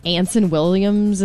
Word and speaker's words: Anson Williams: So one of the stars, is it Anson Williams? Is that how you say his name? Okay. – Mm-hmm.